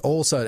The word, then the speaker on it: Olson: It is old son. It is son. Think son. I'm a son of old. Not S-E-N. All the Norwegians spell it wrong. That spell it E-N. I'm an O-N.